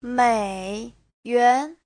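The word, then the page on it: 美元: mei3yuan2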